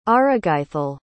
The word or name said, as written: arregaithel